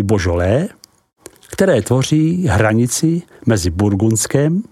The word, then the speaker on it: Beaujolais: a část Beaujolais, vyslovuj Božolé.